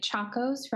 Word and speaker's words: Chacos: I think I'm saying this right. Chacos, right? Is that how you say it? – Yes.